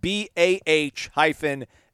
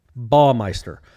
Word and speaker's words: Baumeister: It's on his Twitter profile. He has it phonetically spelled Bah-My-Stir. – So it rhymes with paw and maw. – Correct. – Baumeister.